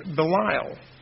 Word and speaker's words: Belial: In the Hebrew, this worthless person, in Hebrew it's Belial. Belial. It's spelled B-E-L-I-A-L. That's the Hebrew word for a worthless person. Belial, it's actually, I think, pronounced Belial, means worthless, destruction, wickedness, evil, and ungodly.